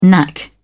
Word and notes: knack: British English

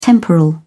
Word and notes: temporal: British English

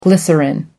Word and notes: glycerine: American English

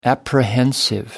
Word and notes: apprehensive: American English

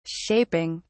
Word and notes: shaping: British English

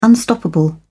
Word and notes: unstoppable: British English

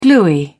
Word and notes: gluey: British English

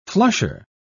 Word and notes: flusher: British English

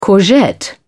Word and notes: courgette: British English

